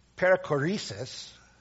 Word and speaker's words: perichoresis: Perichoresis, from the Greek peri, around, and chorying, to make room for and contain.